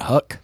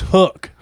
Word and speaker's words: HUK: How do you say it? – Is it not Huck? – That's what I would say. So. – Yeah. It's Hook.